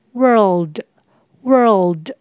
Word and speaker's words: world: 小心 world 的发音，这边的 or 是 er 的卷舌音，那 l 舌头要稍微抬高，所以会念成 world world。